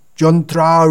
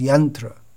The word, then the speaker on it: yantra: but the standard pronunciation is yantra, and Kotriya.